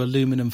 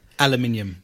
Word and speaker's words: aluminium: So Whatever's a shiny side to aluminum foil. You don't say aluminum, do you? – you yes, we do. You say aluminum. – Aluminum. – Well, how do you say it? – Aluminium.